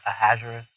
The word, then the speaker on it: Ahasuerus: The rabbis look at this and they say this applies to Esther before she goes before Ahasuerus. So the way you say Ahasuerus, how do we say it in English? Ahasuerus or Ahasuerus or whatever. – Yeah, Ahasuerus.